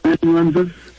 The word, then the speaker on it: amanuensis: Yes, it's A-M-A-N-U-E-N-S-I-S. So it's amanuensis. Exactly how it sounds. – Thanks. And Luke, on the previous call, Luke wrote it himself. He was not using the amanuensis?